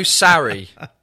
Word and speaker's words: Sarri: Come on, get your Maurizio Sarri. – Yes? – That's how you pronounce it in English.